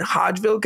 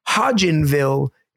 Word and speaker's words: Hodgenville: I've been saying Hodgeville, Kentucky, but apparently it's Hodgenville, Kentucky.